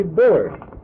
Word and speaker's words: Bullard: B-O-U-L-L-I-A-R-D. Sure, it's B-U-L-L-A-R-D. – Who did you call? – Robert A. Bullard. – G-U-B-O-U? – B-U. – B-U. – B-U-L-L-A-R-D. Bullard. Got to be pronounced that way. He's no more French than I am.